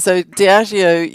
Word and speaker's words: Diageo: So Diageo, you nailed it.